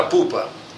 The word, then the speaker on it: Pupa: tem uma Hassidut chamada Pupa. Em português, se escreveria P-U-P-A.